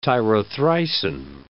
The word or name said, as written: tyrothricin